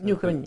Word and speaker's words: Newcomen: the Newcomen, is that the way you pronounce it? – Newcomen, yeah.